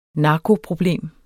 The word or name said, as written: narkoproblem